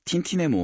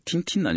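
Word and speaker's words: Tintin: 제가 어렸을 때, 정말 저하고 붙어 있었던 틴틴은요, 틴틴 아니고 저희 때는 땡땡의 모험이라고 표기가 됐었거든요. 그러니까 뭐, 프랑스, 에르제는 벨기에 사람이지만 프랑스어니까요, 이 영어로 했을 때 틴틴 발음이겠는데, 그게 프랑스어로는 땡땡은 아니지만, 하여간. 이쪽이 더 가깝겠죠?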